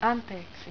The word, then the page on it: Anteeksi: Finnish